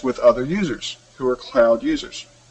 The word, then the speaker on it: Qloud: And finally, I wanted to talk about Cloud, which is spelled Q-L-O-U-D.